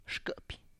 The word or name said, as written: xkIpy